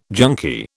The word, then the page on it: junkie: British English